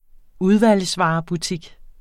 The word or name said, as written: udvalgsvarebutik